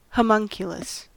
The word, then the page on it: homunculus: American English